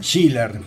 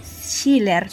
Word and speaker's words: Schiller: Schiller. – Schiller.